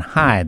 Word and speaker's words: Hey: It looks like Hay, H-E-Y, but it's pronounced Dr. John High, that is, of course.